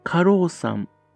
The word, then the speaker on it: 鹿狼山: 過労さん、ね、あの、鹿と狼と山って書いてね、過労さんと読むんですかね。